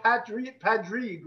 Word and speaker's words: Padraig: Also, the way you guys spell, like the name Patrick, you guys spell it P-A-D-R-A-I-G. Like, where did that come from? – No, it's P-A-T. – Oh, I've seen it spelled Padraig, like P-A-D-R-I-G. Oh, Padraig, that's the Irish language word.